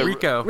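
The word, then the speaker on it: Ricoh: Oh, the Ryko.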